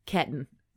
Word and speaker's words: Ketahn: K-E-T-A-H-N. Ketten.